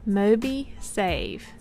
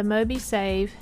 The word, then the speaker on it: MobiSave: And the last one we're going to talk about, if I'm saying this right, Mobi Save. So the more we save, it sounds like you're saying, but it's MOBISAVE.